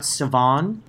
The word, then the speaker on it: Sivan: I think it's pronounced Sivan, because when you hear Sivan, I hear Savannah, who was the evil scientist arch enemy of Dr. Savannah, yeah, with the worm.